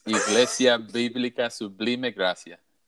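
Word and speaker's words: Iglesia Bíblica Sublime Gracia: You pronounce the name of the church really well, brother. It's Iglesia Biblica Sublime Gracia.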